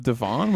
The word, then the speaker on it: divan: You said Divian. Instead of divan, which I don't even really know what that is.